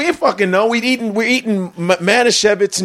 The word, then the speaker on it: Manischewitz: He goes like this, Manischewitz. It's Manischewitz. – You're like, but there's no V in there.